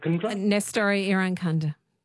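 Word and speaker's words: Nestory Irankunda: Nestori Irankunda? – Yep. Nestori Irankunda.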